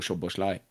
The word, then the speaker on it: Szoboszlai: Och jag tycker det största avbräcket som var närmast till spel, det var Szoboszlai, för han hade behövts. – Ja, men det håller med. Det uttalet har du jobbat på.